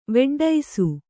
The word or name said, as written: ವೆಡ್ಡೈಸು